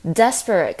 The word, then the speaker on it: desperate: 那我们在发音的时候，我们可以发现 desperate 跟 desperately 它们的重音节是放在强调前面的 des 这个部分，就是 desperate、 desperately。